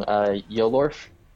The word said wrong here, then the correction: Yorl: Yorl, so it's Y-O-R-L, Yorl, like Yorl. – Oh, Yorl, like, gotcha. – Yeah, almost like Laurel.